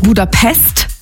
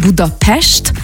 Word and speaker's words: Budapest: appreciaten wir das schon mal, wenn man nicht Budapest sagt, sondern Budapest, ja. Weil das S immer ausgesprochen wird wie ein Sch.